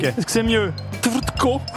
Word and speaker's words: Tvrtko: Est-ce que c'est Stefan Trutko, TVRTKO, le premier.